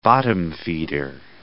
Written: bottom-feeder

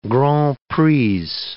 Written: grands prix

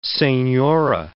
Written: senora

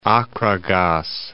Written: Acragas

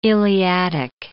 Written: Iliadic

